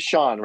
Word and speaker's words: Sean: Well, my first name is Sean, right? Spelled S-E-A-N, which apparently, despite the existence of Sean Connery, nobody can also seem to pronounce.